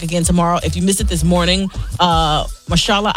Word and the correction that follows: Mahershala: Ali stopped by Mahershala. Because that man pronounced his name 15 different times on that show this morning. And I still had the audacity to say it wrong.